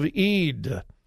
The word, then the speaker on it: Eid: That's E-I-D in English. It's pronounced Eid. In Arabic, it's different.